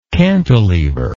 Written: cantalever

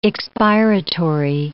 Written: expiratory

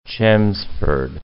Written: Chelmsford

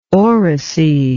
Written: oracy

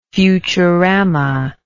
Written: futurama

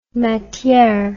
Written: matiere

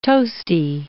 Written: toasty

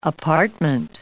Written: apartment

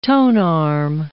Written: tonearm

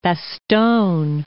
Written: Bastogne

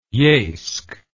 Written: eisk

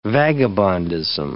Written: vagabondism

